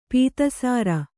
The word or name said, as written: ಪೀತಸಾರ